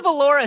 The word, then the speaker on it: Valor: How is the Valora spelled? – You know what? In fact, I checked the pronunciation of that earlier today. It's just Valor, V-A-L-O-R.